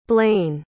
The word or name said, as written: blane